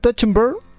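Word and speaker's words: Trachtenberg: Dan touchenberg que ya dirigió Ten Cloverfield Lane, gran película.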